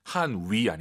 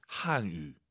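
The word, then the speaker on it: 汉语: 한위 아니고 입술 동그랗게 말아보세요. 동그랗게. 유, 유, 한유. 한유.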